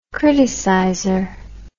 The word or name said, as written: criticizer